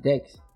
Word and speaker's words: Dax: D-A-X. – Yeah. – Yeah. – You pronounce it Dex.